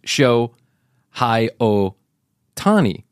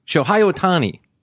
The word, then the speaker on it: Shohei Ohtani: His name is Shohei Ohtani. Shohei Ohtani. Shohei Ohtani.